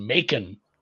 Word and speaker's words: Macon: It is Macon, Georgia. It is a hard C with that one.